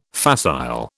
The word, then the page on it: facile: British English